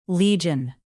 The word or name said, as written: legion